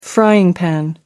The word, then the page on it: frying-pan: American English